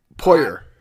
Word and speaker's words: Poirier: The only other thing in sports that I thought was pretty interesting is the McGregor and how do you pronounce his name? Poirier? Is it French? P-O-I-R... – Poirier.